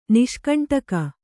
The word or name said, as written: ನಿಷ್ಕಂಟಕ